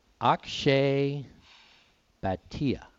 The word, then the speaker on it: Akshay Bhatia: And I remember when this guy first came out on the tour, and his name is pronounced Akshay Bhatia.